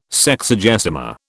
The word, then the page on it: Sexagesima: British English